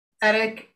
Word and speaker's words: etic: So I think it's pronounced edic and emic, 99% sure.